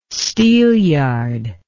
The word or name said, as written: steelyard